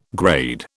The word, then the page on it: grade: British English